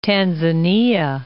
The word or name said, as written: Tanzania